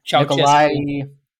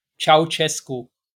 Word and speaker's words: Ceaușescu: Romanian dictator Nicolae Ceaușescu. – Was it? – Ceaușescu.